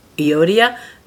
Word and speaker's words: Euodia: He says, I entreat Euodia and I entreat Syntyche. If you were wondering how to pronounce those, hopefully that's helpful. Euodia and Syntyche.